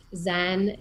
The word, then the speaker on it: Xen: So right now I'm affiliated with Zen, and it's spelled with an X, so X-E-N, Zen Wellness.